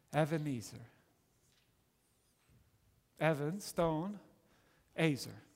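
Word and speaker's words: Evan (אבן): The word Eben, it's actually pronounced Evan in Hebrew, means stone. Evan means stone in Hebrew.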